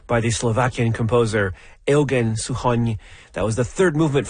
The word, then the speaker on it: Suchoń: It's S-U-C-H-O-N, and the N has a hatchet on it, which is why it's Suchoń.